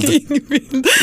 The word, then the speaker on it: Ingvild: Ingvalid? – Ingvalid. jag tror det heter Ingvild. Ingvild.